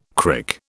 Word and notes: crick: British English

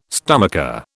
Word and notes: stomacher: British English

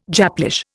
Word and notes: Japlish: Indian

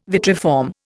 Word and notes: vitriform: Indian

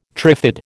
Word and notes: trifid: American English

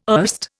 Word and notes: erst: Indian

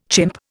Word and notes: chimp: Indian

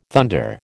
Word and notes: thunder: American English

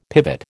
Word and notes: pivot: American English

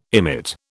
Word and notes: emit: British English

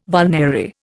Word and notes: vulnerary: Indian

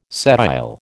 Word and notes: sedile: American English

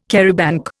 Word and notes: charabanc: Indian

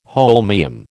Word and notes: holmium: American English

